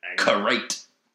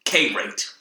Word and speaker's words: karate: I'm gonna go with the anglicized version because I'm an K-rate. – K-rate. – K-rate, yep.